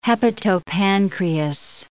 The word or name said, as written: hepatopancreas